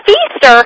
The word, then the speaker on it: Feaster: It's Feaster, kind of like feast or famine.